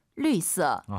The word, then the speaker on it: 绿色: 绿色。 – 아하, 绿色, 유땡땡. 엘유땡땡, 리요 할때그 발음이긴 한데 성조가 다르긴 하죠. 리 기억하시고요.